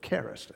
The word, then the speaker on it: charis: Now, the word forgave there is a great word because it is the Greek word charis, or charis, actually, a right pronunciation, C-H-A-R-I-S.